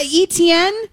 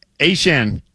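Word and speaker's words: Achane: And so apparently, Ashan, who was the artist formerly known as ETN, says that it's always actually been Ashan, but we've always been pronouncing it wrong with ETN.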